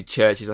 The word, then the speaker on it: CHVRCHES: Uh, obviously, CHVRCHES, I think you said that's best way to pronounce her name. I think that's how it um, is.